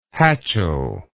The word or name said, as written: hatchel